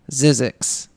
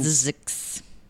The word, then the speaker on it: Zzyzx: Finally, the third California city is called Zizix. – Mm-mm. – It's spelled Z-Z-Y-Z-X. And Zizix.